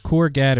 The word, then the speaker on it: Courgette: So anyway, Google or the developers for Chrome have taken it a step further, and they have this new technique called I think you pronounce it Courget. It's Courgette. Courget is how I would say it.